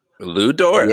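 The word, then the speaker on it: Lu Dort: But is it Lugens Dort? – Lou Dort.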